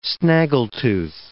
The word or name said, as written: snaggletooth